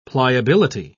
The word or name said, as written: pliability